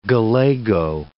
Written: galago

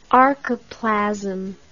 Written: archoplasmic

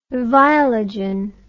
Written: viologen

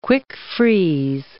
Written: quick-freeze

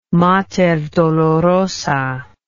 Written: Mater Dolorosa

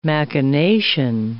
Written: machination

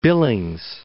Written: Billings

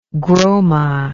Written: groma

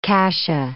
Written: cassia

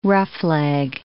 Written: roughleg

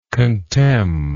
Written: contemn

